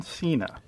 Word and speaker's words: Cena: John Cena.